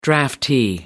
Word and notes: draftee: American English

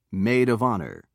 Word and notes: maid of honor: British English

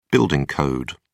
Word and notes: building code: British English

